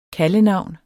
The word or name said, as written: KALDENAVNS